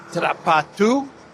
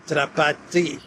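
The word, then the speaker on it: Drapatyi: Some commentators pronounce his last name as Drapatu, but if you go by the Cyrillic spelling of his last name, it would be pronounced as Drapati.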